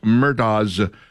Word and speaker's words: Murdaugh: Okay, so yesterday we got this shocking piece of news that the South Carolina Supreme Court overturned Alex Murdaugh's double murder conviction. Some people call him Murdaugh. That's apparently Murdaugh. A regional pronunciation because it's South Carolina. – Right. – I can't bring myself to say that. It's an A-U-G-H, so I'm going to call him Alex Murdaugh.